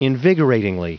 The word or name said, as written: invigoratingly